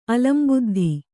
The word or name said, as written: ಅಲಂಬುದ್ಧಿ